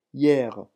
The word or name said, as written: hier